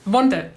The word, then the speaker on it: Wunder: Is it pronounced Wunder in German? – Or yeah, Wunder, perfect. Yeah.